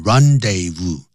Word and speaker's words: rendezvous: The Moulin Rouge was the rendezvous, 就念成 rendezvous, du high life.